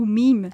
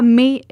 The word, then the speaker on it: Mae: Mae ou Meme? – Ah, Mae, M-A-E. Ouais, j'ai mal prononcé.